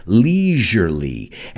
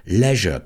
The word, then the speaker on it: leisure: The British pronounce it leisure, leisure, que rima con pleasure. And we say leisure, leisure in the United States.